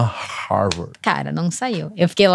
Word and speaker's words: Harvard: Como pronuncia? É Harvard.